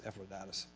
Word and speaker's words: Epaphroditus: Now, other people call it different, but that's how I pronounce it, Epaphroditus.